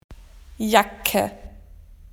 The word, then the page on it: jacket: Australian English